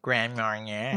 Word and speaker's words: Grand Marnier: I don't know if that's how you pronounce it, but Grand Marnier. – Marnier. You gotta say it fancy.